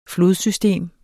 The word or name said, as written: flodsystem